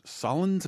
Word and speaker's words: Solondz: I cannot imagine it's very high in demand because the bloom was sort of off the rose with Solondz. Am I saying his name right? – Mm-hmm.